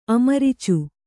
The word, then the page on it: ಅಮರಿಚು: amaricu